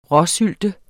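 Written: RÅSYLTE

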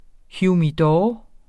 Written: humidor

